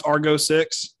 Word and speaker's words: Argo 6: Is it pronounced Argo 6? – Yeah.